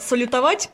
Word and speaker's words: салютовать: Кстати, а ты знаешь, что с легкой руки, вернее, с легкого языка Левитана, слово «салютовать» произносится с ударением на последнем слоге, а не на втором, как это было раньше по нормам русского языка?